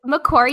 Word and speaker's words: Macquarie: We actually have a girl from, I think it's pronounced Macquarie. Is that right? – Yes. – University in Sydney. – Yes. Macquarie University.